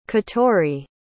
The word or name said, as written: catori